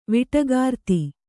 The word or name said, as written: ವಿಟಗಾರ್ತಿ